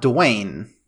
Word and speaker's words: Duane: It's spelled D-U-A-N-E. So it's not Duane. It's like Duane.